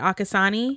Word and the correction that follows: Arcosanti: Arcosanti. – Arcosanti.